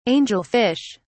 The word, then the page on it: angel-fish: British English